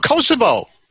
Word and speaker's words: Kosovo: It was a war that was fought in the, I believe it's in Cassava is Eastern Europe. – Oh, all right. Oh, Kosovo. – Kosovo, excuse me.